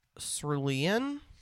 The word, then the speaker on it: cerulean: Okay, so apparently the pronunciation is cerulean.